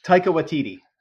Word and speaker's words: Taika Waititi: So, I'm gonna say his name wrong. Taiki. – Taika Waititi. – Taika Waititi. Yeah, there you go.